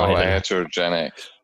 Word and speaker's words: iatrogenic: Oh, iatrogenic.